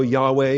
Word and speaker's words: Yahweh: There are no vowels. As such, it's a little bit awkward to pronounce. And so Yahweh is a approximation of the pronunciation.